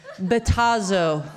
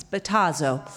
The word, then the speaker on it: Battazzo: This is perfect because my name's actually Katie Batazzo. This is a very important part of the story. So let's say it with me. Batazzo. Yes, you spell it B-A-T-T-A-Z-Z-O. It's Batazzo.